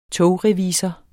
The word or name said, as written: togrevisor